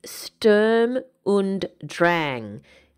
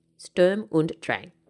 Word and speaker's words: Sturm und Drang: or they might be saying it wrong. Because, ready, it's Sturm und Drang. Sturm, S-T-U-R-M, und, U-N-D, Drang, D-R-A-N-G. Sturm und Drang.